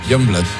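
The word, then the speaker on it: Yungblud: Pero luego lo, ¿cómo se pronuncia? – Youngblood.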